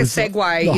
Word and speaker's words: Segue: I thought it was S-E-G-W-A-Y. – You're getting fancy. – Like a segue, you know, like, oh, we're going to segue into the next thing. Is that S-E-G-U-E? – Segue.